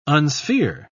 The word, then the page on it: unsphere: British English